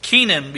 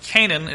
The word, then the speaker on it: Kenan: And Canaan is probably more properly pronounced Kenan, because it is K-E-N-A-N, K-E-N-A-N in 1 Chronicles. So instead of it being pronounced Canaan, it's probably more properly pronounced Kenan, Kenan.